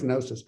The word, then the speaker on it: gnosis: And the other, which is probably the one we're more familiar with, gnosis, starts with a G, but it's pronounced gnosis, that was more experiential learning, learning on the fly, learning through doing.